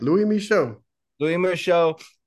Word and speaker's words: Louis Michot: Lou, give me a perfect pronunciation on that name. Louis Michot. – Yeah, that's right. Louis Michot, the album Rêve du Troubadour, 10 tracks.